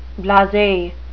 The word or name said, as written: blase